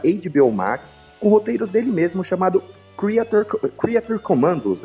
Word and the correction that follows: Creature Commandos: é assim mesmo que se pronuncia, gente? Creature Commandos.